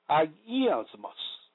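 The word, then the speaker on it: agiasmos (ἁγιασμός): In the above text of Scripture, the Greek word for sanctification is pronounced agiasmos, meaning purification, holiness, or sanctification.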